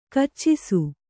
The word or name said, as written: ಕೆಚ್ಚಿಸು